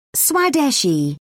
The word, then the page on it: swadeshi: British English